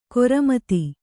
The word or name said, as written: ಖರಾಮತ್ತು